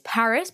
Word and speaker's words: PVRIS: I think it's Paris, but they spell it P-V-R-I-S.